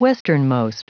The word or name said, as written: westernmost